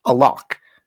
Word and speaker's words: alaq: Now, the Arabic word in question here is, or not really in question, the Arabic word used is alaq. It ends with a Q. It's a Q sound, not a K, in case that's not getting through with my terrible pronunciation.